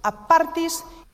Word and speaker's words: apartis: A partis. – ¡Ojo! A Apartis es catalán. Apártese, no es inglés.